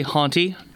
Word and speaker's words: honte: I think it's honte, but I would have to look it up. – Looking it up. It's actually haunty.